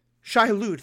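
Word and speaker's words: Shai-Hulud: But one of the things that we're starting to learn is that the spice is connected with the worms, the Shai Hadat. I'm probably pronouncing that wrong. – Shai Hulud, thank you.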